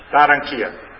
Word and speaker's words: Barranquilla: Barranquilla. – Barranquilla.